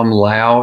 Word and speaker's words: Lowe: Tom Lowe, it's spelled L-O-W-E, like low.